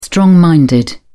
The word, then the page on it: strong minded: British English